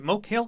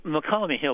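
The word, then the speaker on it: Mokelumne Hill: Is it Moke Hill, California? – Yes, it's McCallamy Hill, but nobody can pronounce it correctly.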